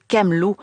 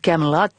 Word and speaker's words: Camelot: Impossible de parler du roi Arthur sans évoquer le légendaire château Camelot ou Camelot, si vous aimez le prononcer en anglais.